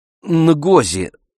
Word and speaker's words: Нгози: «Нгози. Н. Носовое».